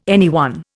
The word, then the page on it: anyone: Indian